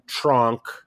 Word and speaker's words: Tronc: where Tronc, is that how you pronounce it? – Yes.